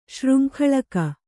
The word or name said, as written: ಶೃಂಖಳಕ